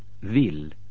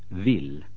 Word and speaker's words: vill: Эль по-шведски всегда произносится мягко, как если бы за ней шел мягкий знак. Например, глагол хотеть. – Vill. Vill.